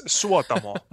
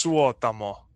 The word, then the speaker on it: Suotamo: I've been practicing because you said that it was like that coffee noise. That's like the best way you can tell Americans how to pronounce it. – Yeah, that's a small part of it, but it's so difficult. I mean, it's Suotamo, but it's so yeah, that's Suotamo.